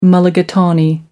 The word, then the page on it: mulligatawny: American English